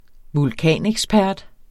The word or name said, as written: vulkanekspert